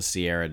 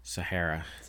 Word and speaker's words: Sahara: Everyone, just roast Jester about his pronunciation of the Sahara Desert, not the Sierra Desert. – Sahara.